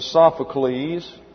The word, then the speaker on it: Sophocles: A-E-S-C-H. I left the C out. A-E-S-C-H-Y-L-U-S. That's Aeschylus. And then Sophocles. S-O-P-H-O-C-L-E-S. And then Euripides. E-U-R-I-P-I-D-E-S.